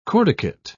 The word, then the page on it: corticate: British English